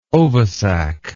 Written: ovisac

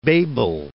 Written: Bebel

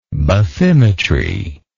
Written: bathymeter

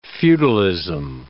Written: feudalism

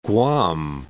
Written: Guam

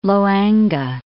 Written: Loange

